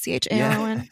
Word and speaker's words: Charon: It's like a personal Karen. Is that how you pronounce it? Charon. Yeah. – Yeah, sure. – Oh, yeah. From the River Styx.